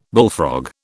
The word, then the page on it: bullfrog: British English